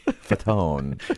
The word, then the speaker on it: Fatone: Fatone. – Yes. Fatone.